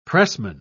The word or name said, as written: pressman